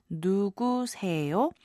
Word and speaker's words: 누구세요: 에요 is how it's pronounced, and put together you get 누구세요.